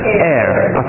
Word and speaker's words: heir: God said about us that we are, how do you pronounce it, heir or hair? – Heir.